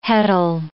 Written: heddle

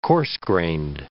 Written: coarse-grained

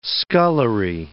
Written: scullery

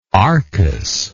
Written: arcas

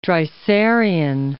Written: Dreiserian

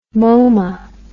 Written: MoMA

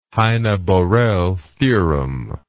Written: heine borel theorem

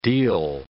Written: deil

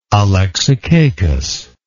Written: alexicacus

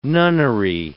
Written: nunnery

